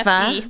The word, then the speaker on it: Aoife: And her name is spelled really awesomely A-O-P. Trippy. Yeah. So it's like, yeah. – But A-O-I-F-E, you say Ifa. – But it's Ifa.